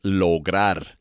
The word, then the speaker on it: lograr: So anyhow, today is ayer, and ayer is spelled A-Y-E-R, ayer. Okay, now the verb of the day today is lograr, and lograr means to achieve, and the way it is spelled is L-O-G-R-A-R, lograr.